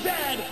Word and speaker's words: Zed: A toot is a hat, a Chesterfield is a coat, and it is pronounced Zed, not Z-Z.